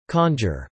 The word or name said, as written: conjure